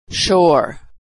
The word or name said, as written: shore